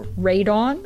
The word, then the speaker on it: Radon: So it's radon, like that gas that you have nowadays that you don't want in your house.